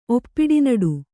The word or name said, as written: ಒಪ್ಪಿಡಿನಡು